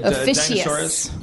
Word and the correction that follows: Ophiuchus: Am I pronouncing it wrong? What is it? Ophiuchus? – I know, right? – Adam Goldberg to the rescue. – Is it Ophiuchus? – Yep.